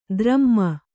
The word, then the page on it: ಧರುಮ: kannada